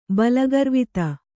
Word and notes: ಬಲಗರ್ವಿತ: balagarvita

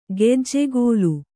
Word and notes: ಕಜ್ಜಗಲಿ: kajjagali

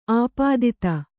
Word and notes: ಆಪಾದಿತೆ: āpādite

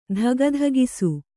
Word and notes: ದಗದಗಿಸು: daga dagisu

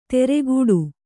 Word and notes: ತೆರೆಗೂಡು: teregūḍu

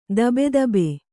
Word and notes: ಧಬಧಬೆ: kannada

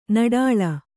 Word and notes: ನಿಟಲ: niṭala

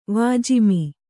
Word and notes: ವಾಜಿಮಿ: kannada

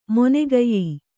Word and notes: ಮಾಣಾಕ್ಯ: māṇākya

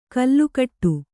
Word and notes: ಕಲ್ಲುಕಟ್ಟು: Kannada

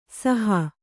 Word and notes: ಸಾಹು: sāhu; kannada

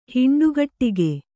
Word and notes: ಹುಂಡಿಗಡಿಗೆ: kannada

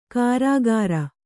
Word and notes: ಕಾರಾಗಾರ: Kannada

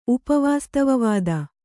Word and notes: ಉಪವಾಸ್ತವವಾದ: upa vāstavavāda

